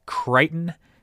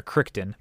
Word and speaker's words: Crichton: And they draft a guy by the name of, I believe it is pronounced Scott Crichton. C-R-I-C-H-T-O-N. It's either Crichton or Crichton, but I'm pretty sure it's Crichton. This is a thing.